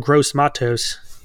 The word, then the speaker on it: Gross-Matos: I think the defensive line still is strong despite losing Etor Gross Matos.